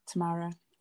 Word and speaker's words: Tamara: I said, like, Tamara, it's spelt the way it sounds, but there was a girl the year above me whose name was Tamara.